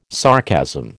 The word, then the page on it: sarcasm: American English